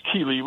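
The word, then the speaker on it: céilí: A keely? – I believe the pronunciation is keely. Well, that's simply a Gaelic word that means informal gathering of musicians.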